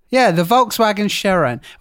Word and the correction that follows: Sharan: Well, Sharan. – Yeah. Yeah, Sharan.